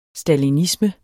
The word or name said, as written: stalinisme